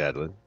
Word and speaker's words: Adlin: Or how do you think he pronounces his name? A-D-L-I-N. – I'd say Adelin. Yeah.